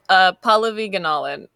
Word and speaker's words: Pallavi Gunalan: I'm so sorry. I said it wrong. Pallavi Gunalan. – It's closer. Yeah, it's Pallavi Gunalan.